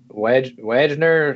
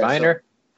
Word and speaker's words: Wagner: His name looks like Wagner or something, or Wagner, yeah, but it's Viner.